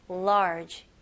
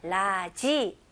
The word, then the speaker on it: large: 제대로 영어로 발음하실 거면 large 이음절로 하셔야 돼요. 오케이, 이거는 많은 연습 통해서 가능합니다. 오케이, 라지 아니고요. large, large. 그리고 마지막으로 J 발음으로 끝내셔야 돼요. 그래서 모음 소리 없이 large.